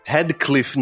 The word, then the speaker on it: Radcliffe: Radcliffe, nossa, era isso.